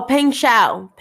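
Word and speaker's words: Peng Shuai: Peng Sheng, right? Is that what I said? – We don't know how to pronounce it. Her name is P-E-N-G and her last name is S-H-U-A-I. – Oh, Peng Shao. So Peng Shao, okay, is an athlete in China, okay?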